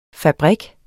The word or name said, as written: fabrik